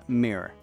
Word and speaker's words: mere: It's pronounced mirror. M E R E.